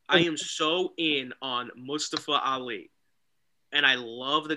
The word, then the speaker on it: Mustafa: I am so in on Mustafa Ali. – Yes. – You said his name wrong. – It's Mustafa. That's right.